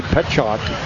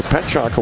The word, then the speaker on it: Pietrzak: That's Cade Petchock. It's like pronouncing Cooper C's last name, but it's, you know, P-I-E-T-R-Z-A-K. And so Petchock, a West Fargo Cheyenne kid.